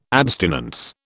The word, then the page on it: abstinence: British English